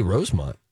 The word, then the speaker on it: Rosemount: Even though I think there's a U back there, isn't there? Sure is. – Then that's pronounced Mount. – You got to pronounce it like the locals. – Do they say Rosemont? – I think so. – I think they do. – They say Rosemont. – Called a football game there before. I'm pretty sure it's Rosemont. Yep.